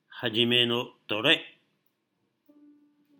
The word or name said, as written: Hajime no To Rei